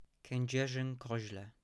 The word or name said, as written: Kedzierzyn Kozle